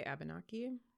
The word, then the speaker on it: Abenaki: So and specifically this is with the Abenaki. I would say Abenaki. Abenaki, that sounds better.